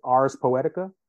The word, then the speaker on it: Ars Poetica: And that was called Ars Poetica. Am I pronouncing that correctly? – Oh, yeah, you're pronouncing it correctly.